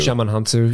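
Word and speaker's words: Djimon Hounsou: I always say Jemin Hansu. – Jemin Hansu.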